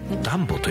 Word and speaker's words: DUMBO: DUMBO と書いてランボと読みます。